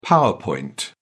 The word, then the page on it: power point: British English